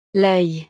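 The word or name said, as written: l'œil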